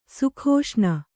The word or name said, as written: ಸುಖಾಸನ